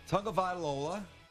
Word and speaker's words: Tagovailoa: So three quarterbacks have dominated the college football season, and all three, Tua, Tagovailoa.